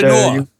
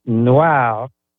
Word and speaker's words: noir: Yes, my nom de noir. – Actually, it's pronounced noir.